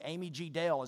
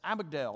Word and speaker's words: amygdala: It's spelled A-M-Y, like Amy, with a G, then Dale, D-A-L-E. Amy G. Dale is how you'd want to say it, but it's amygdala.